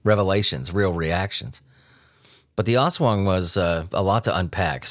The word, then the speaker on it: Aswang: But after the documentaries that I watched and the books that I rented, and it looks like the people there in the Philippines, they pronounce it Aswang.